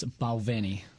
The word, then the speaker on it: Balvenie: It's Balvenie. – Balvenie.